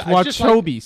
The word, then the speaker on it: Wachobies: And by Wachabi's. It's Wachobies. – Say it right. – Wachobies, excuse me. – I mean, if you're Italian, you can say Wachabi's, but it's Wachobies.